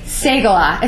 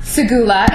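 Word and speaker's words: Segullah: It's Segala. It's not Segula. It's not Segala. Although I, for a year after joining the staff, called it Segala. It rhymes with ooh-la-la.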